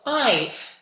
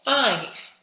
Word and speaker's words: I've: Entonces, para contraer, cuando la forma sea ha, solamente se pone detrás del apóstrofe VE. Y se pronunciaría como si fuese una F. Por ejemplo, sin contraer I have, contraído I've. ¿Veis? I've.